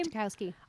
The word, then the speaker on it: Ratajkowski: Ratajkowski.